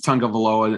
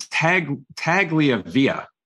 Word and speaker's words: Tagovailoa: I didn't know how to pronounce Tagovailoa until probably last year, but he still can't pronounce it.